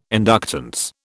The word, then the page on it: inductance: British English